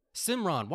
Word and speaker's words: Simran: Shimran. Simran. Why I keep saying Shim? Yeah, where did I get Shim from? – Yeah, I was like, what?